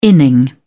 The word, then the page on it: inning: British English